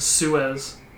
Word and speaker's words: Suez: Okay, so the Suarez, I think that's how you pronounce it, is called the Suarez Canal. – I think you're talking Suez. – Is it Suez? – Suez Canal? – Yeah, the Suez Canal, yes.